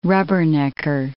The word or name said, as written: rubbernecker